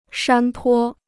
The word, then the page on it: 山坡: shān pō